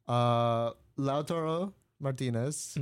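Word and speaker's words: Lautaro Martínez: Lautaro Martinez.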